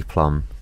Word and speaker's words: Plum: My name is Ali Plum, and your name is Jason Blum.